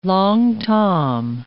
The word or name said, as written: Long Tom